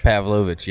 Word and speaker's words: Pavlovicz: Got a couple more later, but I've got Joey P, because I can't pronounce his last name, Pavlovich. – Pavlovich, yep. – Pavlovich. I still can't do it.